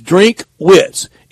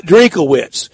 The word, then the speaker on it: Drinkwitz: And it is two syllables. It is Drinkwitz. It's not Drinkowitz. It's not Drink-O-Wits. It's two syllables. Drinkwitz.